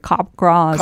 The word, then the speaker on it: Capgras: Pronounced like Capgras. – Capgras delusion. Capgras, named after a French... – C-A-P-G-R-A-S. Capgras, okay.